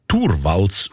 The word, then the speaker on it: Torvalds: Der Linus Torvalds, eigentlich Torvalds ausgesprochen, dazumal Informatikstudent Helsinki, er hat die wertvollste Software der Welt namens Linux einfach verschenkt.